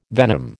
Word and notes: venom: American English

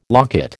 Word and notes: locket: American English

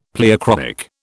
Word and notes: pleochroic: British English

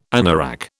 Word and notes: anorak: British English

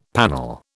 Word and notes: panel: British English

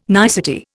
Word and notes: nicety: Indian

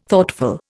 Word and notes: Thoughtful: Indian

